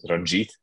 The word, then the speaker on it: Ranjit: It's my real name, or my official name, Indian name, is Ranjit, which is spelled R-A-N-J-I-T.